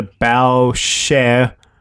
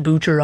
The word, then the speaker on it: Boucher: Chris Boucher is pronounced closer to Bow Share. First part, like the front of a ship, and the second part, like the singer Cher.